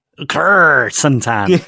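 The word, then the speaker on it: Krrsantan: Kursantan. It is spelled K-R-R, Santan, isn't it? – Yeah, basically.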